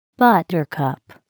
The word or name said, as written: buttercup